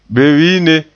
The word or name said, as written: bewiine